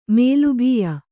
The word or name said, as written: ಮೇಲುಬೀಯ